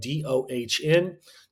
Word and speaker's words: Thornton: My name is spelled DONTHORNTON /30 minutes.